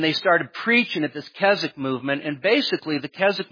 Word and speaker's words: Keswick: Then there was the Keswick Convention, and that's Keswick, but don't pronounce the W.